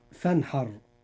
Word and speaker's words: فانحر: كلمة فانحر، الراء في هذه الكلمة مفخمة لأنها ساكنة وما قبلها مفتوح، فتنطق مفخمة هكذا. – فانحر.